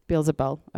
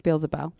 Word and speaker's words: Beelzebul: They lied and mocked and said, surely he is possessed by Beelzebub, the prince of the demons that drives out demons. That he drives out demons like that. How do you say that name? Beelzebul. Okay, Beelzebul. Anyways, he was accused of being possessed by Beelzebul demons.